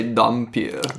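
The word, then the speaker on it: dhampir: Alucard, who is the son of Dracula, and I think this is called, is a Dampier. Is that how you pronounce it? – I would just say Dampier. – Dampier. – Yeah.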